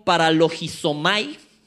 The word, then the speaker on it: paralogizomai: Esta palabrita engañándoos viene del griego paralogizomai, para con G, para y acento en la I, en la primera, para Significa razonar a un lado o justificarse de paso.